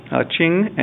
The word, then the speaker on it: Qing: And I'm sitting here with two of the three authors of some books on IPv6, Qing and Jinmei.